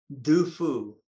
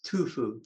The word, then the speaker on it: Du Fu: His name was Du Fu. They spell it D-U-F-U. But in the old day, it was Tu Fu, T-U-F-U.